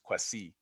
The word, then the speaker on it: Kwasi: So like, for example, my name is pronounced Kwasi, and mm-hmm.